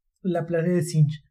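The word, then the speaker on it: La Planète des Singes: ¿Cómo se pronuncia en francés esto? – La Planète de Singes.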